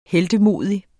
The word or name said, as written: heltemodig